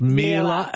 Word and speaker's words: Miele: It's pronounced Miele. It rhymes with Sheila.